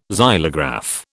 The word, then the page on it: xylograph: British English